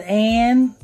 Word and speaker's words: Anne: It depends on, some people spell it Anne, some people spell it Annie. – Okay, yeah. So I'm just gonna stick with Anne. Keep the E silent.